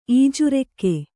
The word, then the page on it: ಈಜುರೆಕ್ಕೆ: ījurekke; kannada